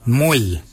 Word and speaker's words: mooly: Warago is spelled W-O-R-R-A-G-O-L. Warago. Warago is your upper mouth. For your nose, we say moil. Moil is your nose. Moil is spelled M-O-O-L-Y.